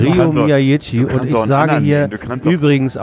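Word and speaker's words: Miyaichi: Der Mann heißt Rio Miyagi und wird gefälligst auch so ausgesprochen.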